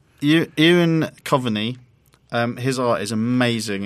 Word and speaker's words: Eoin: But the art by Ewan... How do you say E-O-I-N? I think it's Ewan. Ewan? – I don't even know what you're saying to me now. – The name, E-O-I-N.